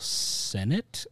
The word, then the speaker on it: Sennott: Emma Seligman is the writer-director, and then Rachel Sennett, is how you say it.